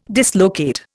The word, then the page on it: dislocate: Indian